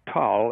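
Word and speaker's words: Tal (تل): And he said the size of it and of the tell, and by the way, you pronounced it correctly, Tal in Arabic, but Tel in Hebrew.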